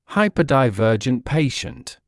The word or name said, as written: hyperdivergent patient